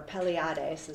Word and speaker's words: Pleiades: So what they believe is, and that the word that they used to call was Pleiades, or Pleiades is how you pronounce it.